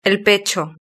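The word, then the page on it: el pecho: Spain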